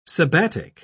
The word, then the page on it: sabbatic: British English